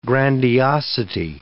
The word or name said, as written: grandiosity